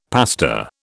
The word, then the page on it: pasta: British English